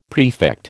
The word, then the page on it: prefect: American English